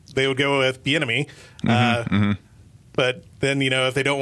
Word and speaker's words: Bieniemy: They're looking at Eric Bieniemy. Am I saying that right? – Yes.